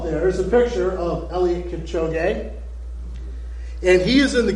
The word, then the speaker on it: Eliud Kipchoge: And Eliud Kipchoge,